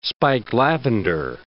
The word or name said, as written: spike lavender